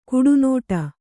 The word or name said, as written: ಕಡುನುಡಿ